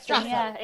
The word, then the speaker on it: Streatham: Streatham.